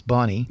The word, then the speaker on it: Boinu: But his wife just had major Bonnie, B-O-I-N-U, but pronounced like we would pronounce Bonnie.